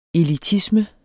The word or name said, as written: elitisme